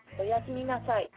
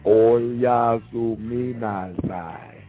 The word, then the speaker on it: Oyasuminasai: Oyasuminasai. – Oyasuminasai. – All right, and that's spelled OYASUMINASAI. Oyasuminasai. – Oyasuminasai. Oyasuminasai. Oyasuminasai. – Oyasuminasai. Yeah, oh yeah, you come inside. That's what it sounds like, motherfucker.